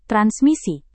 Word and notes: transmisi: Indonesian

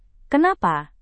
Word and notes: kenapa: indonesian

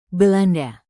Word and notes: belanda: indonesian

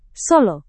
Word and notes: solo: Indonesian

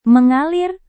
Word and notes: mengalir: Indonesian

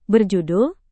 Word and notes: berjudul: Indonesian

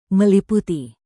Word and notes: meliputi: Indonesian

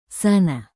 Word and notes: sana: indonesian

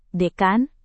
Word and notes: dekan: Indonesian

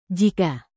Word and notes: jika: Indonesian

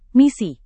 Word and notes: misi: Indonesian

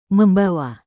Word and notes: membawa: Indonesian

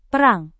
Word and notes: perang: indonesian

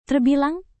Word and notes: terbilang: indonesian

